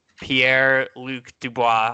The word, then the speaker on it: Pierre-Luc Dubois: the most recent news is Pierre-Luc Dubois.